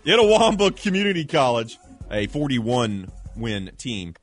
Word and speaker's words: Itawamba: Itawamba. – Okay. – Actually sounds right.